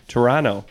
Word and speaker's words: Toronto: He's a Triviality superstar from, as he says it, Toronto, because you don't pronounce the second T.